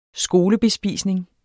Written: skolebespisning

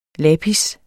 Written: lapis

